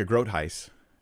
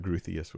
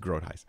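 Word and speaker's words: Groothuis: So another one is, this is from Rebecca Grotheis. Did you get that? I pronounced her name right, guys. Sorry, I did that wrong before. It's spelled Grotheius, so I thought Grotheus was correct, but it's Grotheis.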